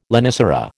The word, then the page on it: lonicera: American English